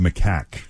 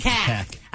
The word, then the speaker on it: macaque: And I'm being told that it's pronounced macaque. – It is. – Macaque. – I was actually going to tell you that when we went into the break. They said macaw is a bird and macaque is the monkey.